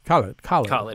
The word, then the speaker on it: Khaled: Khaled or Khalid? – Khaled.